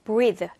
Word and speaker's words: breathe: 그 다음에 the 라고 소리를 내서 성대를 울려서 유성음 소리로 내셔야 돼요. the 그래서 breathe 라고 발음하셔야 되고요.